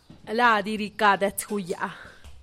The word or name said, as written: ladyrikadetsguyaa